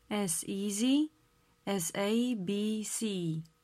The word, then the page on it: as easy as abc: slow